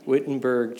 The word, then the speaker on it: Wittenberg: Wittenberg Church in Germany.